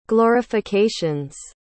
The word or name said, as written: glorifications